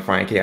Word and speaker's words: Franke: You know, since you're American, let's go with that. You're right, it's Jan Franke.